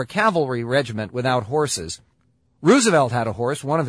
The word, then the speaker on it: Roosevelt: He preferred Roosevelt as the pronunciation.